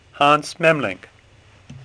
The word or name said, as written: Memling